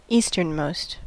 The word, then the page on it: easternmost: American English